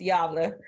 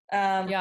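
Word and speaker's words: Gävle: It's spelled G-A-V-L-E, but the A has a bunch of little funky stuff over it. So I went to YouTube and found out that it's pronounced Yavle. Yavle.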